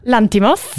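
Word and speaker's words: Lanthimos: Lantimos.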